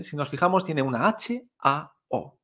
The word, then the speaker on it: hao: Y el sonido inicial de esta, hao, es la H que no se pronuncia como el español, sino que no se pronuncia hao.